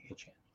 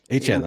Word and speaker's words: Achane: Or Achane, excuse me, Achane. – Achane, that's right. Yeah, we know now. We know now how to say it, so we can.